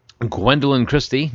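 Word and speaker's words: Gwendoline Christie: Also, Gwendolyn Christie, actress known for playing Brienne on Game of Thrones.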